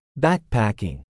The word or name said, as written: backpacking